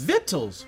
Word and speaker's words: victuals: Vittles. Why is there a C in there? I don't like this. – Hey, man. – I don't like these silent letters.